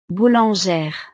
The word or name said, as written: boulangere